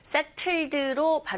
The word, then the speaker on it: settled: Settled로 발음할 수도 있지만 to 사운드가 네, 바로 모음 사이에서 으, 르에 가까운 소리로 나기 때문에 settled 이렇게 발음해 주시면 돼요.